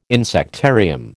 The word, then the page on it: insectarium: American English